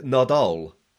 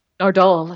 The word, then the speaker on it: Nardole: And there's poor Nardole, or Norton. I can never pronounce it. Is it Nardole or Noodle or what the? – Well, the way Capaldi says it, is Nardole. – Nardole, that's right.